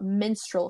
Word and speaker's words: minstrel: he talks about minstrel shows, and I had to look up how to pronounce it, because it sounded like menstrual, but it's not, obviously, a girl's period.